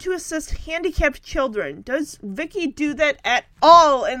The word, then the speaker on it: VICI: The storylines revolve around Vicky, V-I-C-I, an acronym for Voice Input Child Identikit, pronounced Vicky, V-I-C-K-I, an android in the form of a 10-year-old girl.